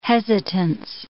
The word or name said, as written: hesitance